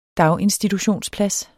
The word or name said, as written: daginstitutionsplads